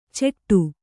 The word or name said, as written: ಚೆಟ್ಟು